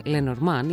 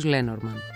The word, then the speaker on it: Λενορμάν: Παρότι η σωστή προφορά του ονόματος είναι Λενορμάν, η οδός επικράτησε ως Λένορμαν.